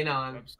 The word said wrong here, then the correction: QAnon: Did you say Kanon? I thought it was QAnon. – QAnon or whatever. QAnon, Kanon, like that. Q K. Pronounce, I don't know how much we want to go into this, but yeah.